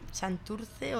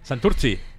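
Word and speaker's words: Santurtzi: hemos salido fuera, sobre todo, pues lo que más, porque también una vez tocamos en Santurce o Santuchu, ya no sé cómo se dice. – Santurchi.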